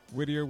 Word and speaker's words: Whittier: Whittier with the girls are prettier.